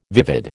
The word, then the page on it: vivid: American English